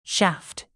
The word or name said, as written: shaft